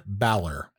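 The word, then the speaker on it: Bálor: We got Rey Mysterious and Dominic Mysterious and Finn Bay. How do you pronounce the A with the accent aigu? – Finn Balor.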